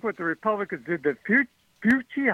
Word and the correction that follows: Fauci: how do you say that name? – Fauci.